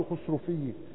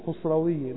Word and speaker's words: الخسروية: من الخطأ أوقات بيقولوا خسروفية، حتى مرة كتبوا عليها المدرسة الخسروفية. في اللغة التركية الحالية اللاتينية يكتبون الواو الظا، يعني في لغة الترك الفا تلفظ وا، فمن ترجم الحرف اللاتيني ظن خصرفية جهلا منه، ولكن لو ترجمها تركي ايش بقول؟ خسروية، لانه الفا واو تلفظ عنده.